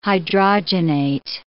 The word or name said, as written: hydrogenate